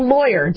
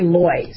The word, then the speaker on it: lawyer: When you have a lawyer, do you pronounce it loy, like L-A-Y, or lawyer? I say loy, so rhymes with boy.